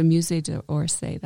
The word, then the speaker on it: Musée d'Orsay: The Musée d'Orsay, that's right.